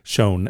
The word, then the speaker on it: Shoen: Shown, shown.